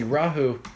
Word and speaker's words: Rahu: If we look at Rahu, see Rahu, if you notice, has a long A.